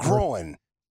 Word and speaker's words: groin: Mike struggles with groin. He calls it a growing. – Yeah. Strange thing that groin. – Growing. – Am I saying it wrong too? Growing? – I think you got it. – It's like coin. Groin. Everybody tells me it rhymes with coin.